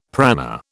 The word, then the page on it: prana: British English